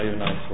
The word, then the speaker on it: Yonai: Yonai, it rhymes with Guy. – Yonai. Please proceed. – May it please the court, Guy Yonai for Source Vagabond, the appellant.